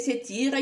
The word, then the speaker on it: Satir: C'est la fille de Satire, prononcée Satire,